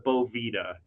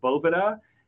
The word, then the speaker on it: Boveda: So a lot of growers call Bovita Bovita. And I saw in the chat there some people say I mispronounce it. It's a common misconception. When I actually first started talking to the company, I called it Bovita as well. But it is actually called Bovita.